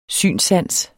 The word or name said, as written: synssans